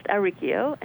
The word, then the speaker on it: Auricchio: It's pronounced Auricchio, and it's spelled Auricchio.